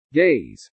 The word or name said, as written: gaze